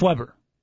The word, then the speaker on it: Weber: Even though it's spelled Weber. – But it's pronounced Weber. – Yes, Coach. It's kind of like the Weber Grill.